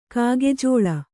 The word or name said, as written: ಕಾಗೆಜೋಳ